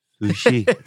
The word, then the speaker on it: sushi: Sushi. Lite betoning på i-et.